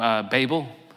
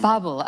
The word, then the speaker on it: Babel: Babel. I'm pronouncing his second name. Yes, Babel.